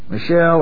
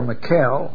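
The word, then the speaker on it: Michele: The shark, Michelle or Mikkel or Mikkeli, pronounced various ways, Sindona.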